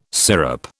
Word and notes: syrup: British English

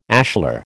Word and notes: ashlar: American English

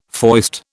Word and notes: foist: British English

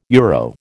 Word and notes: euro: American English

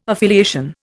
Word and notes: affiliation: Indian

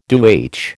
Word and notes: DoH: American English

